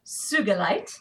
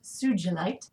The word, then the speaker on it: sugilite: Sugalite, often mispronounced sugilite, was discovered in 1944 by Japanese gemologist Kenichi Sugi. And it's a hard G, and it is mispronounced all the time because no one knows how to pronounce Japanese names. No one outside of Japan. So it's sugalite. It is an opaque to translucent crystal.